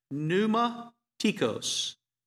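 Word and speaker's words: pneumatikos (πνευματικός): So spiritual gifts is taken from a single Greek word which is pronounced pneumatikos, and that can be translated one of two ways, spirituals or spiritualities, people or things.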